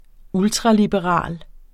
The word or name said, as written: ultraliberal